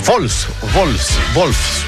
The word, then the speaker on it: Wolfsburg: No, ese Wolfsburg, porque la W se pronuncia como V corta, ¿no? O V, se dice V.